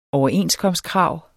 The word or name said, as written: overenskomstkrav